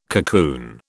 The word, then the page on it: cocoon: British English